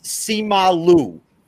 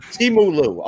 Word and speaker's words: Simu Liu: And I'm not sure if I'm pronouncing this correctly, but his name is Simu Liu, I believe, if that's how you pronounce it. Grandmaster, Simu Liu. Is that right? Simu Liu.